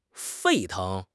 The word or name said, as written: 沸腾